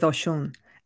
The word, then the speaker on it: Oshun: So we start with Oshun, and she has different pronunciations, but this is the Cuban pronunciation.